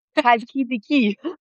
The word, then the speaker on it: Chalkidiki: Wie spricht man es aus? – Chalkidiki. – Ja, ich lasse es sein.